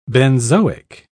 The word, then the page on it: benzoic: British English